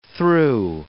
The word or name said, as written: threw